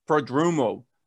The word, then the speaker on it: Prodromou: I'm excited to say hello to Mr. Ted Prodromou.